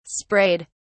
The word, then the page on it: sprayed: British English